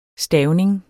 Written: stavning

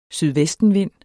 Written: sydvestenvind

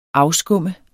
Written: afskum

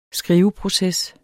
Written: skriveproces

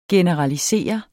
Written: generalisere

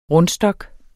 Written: rundstok

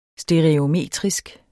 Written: stereometrisk

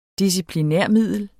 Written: disciplinarmiddel

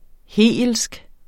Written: hegelsk

